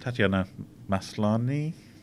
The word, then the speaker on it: Tatiana Maslany: Tatiana Maslany, I believe, is the way you pronounce it.